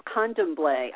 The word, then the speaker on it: Candomblé: First, thank you for some of those pronunciations. Candomblé, I've never actually heard someone say it before.